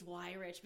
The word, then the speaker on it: Weyrich: If you look back at all the podcasts I've done recently, I think the first thing I say is, I just gotta say it's why rich because nobody knows.